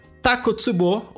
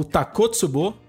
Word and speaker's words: Takotsubo: Takotsubo, ou Takotsubo, ou Takotsubo, você escolhe a pronúncia preferida aí.